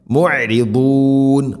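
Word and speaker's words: മുഅരിദൂൻ (معرضون): മുഅരിദൂൻ, ദാദിൻ്റെ ഉച്ചാരണം വളരെയധികം ശ്രദ്ധിക്കണം. സൂറത്തുൽ ഫാത്തിഹയിലൊക്കെ ഗൈരിൽ മഗ്ദൂബി വലദ്ദാ, ഇതെല്ലാം ശ്രദ്ധിക്കണം ഇൻഷാല്ലാ.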